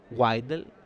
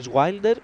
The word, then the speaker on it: Wilder: για τον Wilder. Wilder. Σωστά. Έτσι προφέρεται.